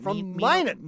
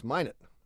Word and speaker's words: Minot: Minot. Mino? – No, it's Minot. – Well, I know they pronounce it Minot, but isn't it Mino? – They pronounce it Minot.